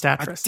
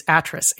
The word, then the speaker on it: Atrus: It is pronounced Atris. – Oh, damn it. I did it again. – The one in the game is Atris, A-T-R-U-S.